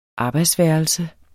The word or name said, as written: arbejdsværelse